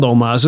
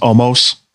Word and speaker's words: Omos: I think they failed Omos. This would be much better if the build was better. – No, yes. You see, we're all saying it wrong. Brock said it right. It's Omos.